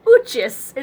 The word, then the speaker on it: Uchis: It's by Callie Uchis, is how I've decided to pronounce the last name, because it's U-C-H-I-S, Uchis. – And it's Callie, I think it's K-A-L... – K-A-L-I.